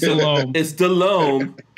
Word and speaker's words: Delhomme: Delhomme. Oh, see, I did it again. It's Delhomme. It's not Delhomme. I said Delhomme. It's not Delhomme. It's Delhomme. Delhomme. Okay, Jake Delhomme.